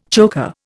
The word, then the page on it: choker: Indian